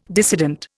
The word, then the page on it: dissident: Indian